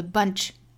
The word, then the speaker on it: bunch: There's some bad apples in the bunch, in the bunch. 还有一个要点呢，就是 bunch 这个词哈，里面是一个倒三角啊的发音，大家不要开口过大，不要读成了 ba, bunch, bunch，应该是一个啊，在我们口型最自然的的这个状态之下，下巴再略微向下降一点点，发成啊。 Bunch, bunch，后面的这个 B-U-L-K